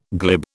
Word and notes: glib: British English

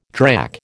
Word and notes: drack: American English

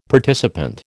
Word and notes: participant: American English

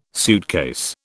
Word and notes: suitcase: British English

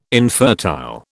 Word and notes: infertile: British English

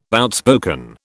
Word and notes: outspoken: British English

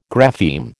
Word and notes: grapheme: American English

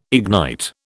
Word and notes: ignite: British English

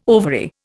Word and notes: ovary: Indian

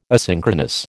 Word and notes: asynchronous: American English

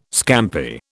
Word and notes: scampi: British English

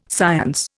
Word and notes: science: Indian

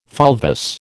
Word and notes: fulvous: American English

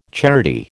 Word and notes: charity: American English

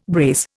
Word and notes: brace: Indian

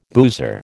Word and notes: boozer: American English

